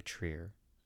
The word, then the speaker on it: Trier: Trier, the city of Trier, I believe is how you pronounce it.